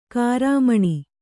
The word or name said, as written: ಗ್ರಾಮೀಣ